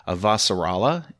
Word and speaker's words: Avasarala: She's continued to play supporting and character roles in film and television, including a starring role as Christian Avas, if I get the pronunciation right, Avasarala in The Expanse. Yeah, Christian Avasarala.